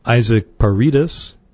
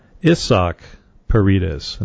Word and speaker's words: Paredes: I was calling him Isaac Paredes. He's a mainly third baseman, all-round infielder for the Tampa Bay Rays. But apparently his name is pronounced Isaac Paredes, and not Isaac Paredes.